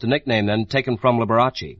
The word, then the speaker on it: Liberace: It's almost the Italian pronunciation. In Italian, you roll the R a little bit. You say Liberace. – Liberace.